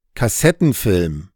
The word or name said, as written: Kassettenfilm